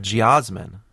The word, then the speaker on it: geosmin: They taste like soil because they're carrying a compound called geosmin, which is spelled G-E-O-S-M-I-N, geosmin, which is the earth scent.